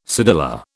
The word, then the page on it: cedilla: British English